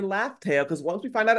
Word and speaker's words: Laftel: commented on so many Morge videos when he kept saying Raftel. – I'm like, Every time I would comment and say it's Laftel, by the way.